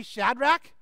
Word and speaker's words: Shadrach: I did a little research, and the correct pronunciation, repeat after me, Shadrach, Meshach, Abednego. Okay, that the third one's the one we always get wrong, but Abednego, and the fourth is Daniel, but we're going to focus on the first three.